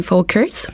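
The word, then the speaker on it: Völkers: Alors, je travaille pour Engel and Volkers. Euh, puisque c'est allemand, c'est Engel. La majorité des gens disent Engel Volkers, mais il faut prononcer le V comme un F.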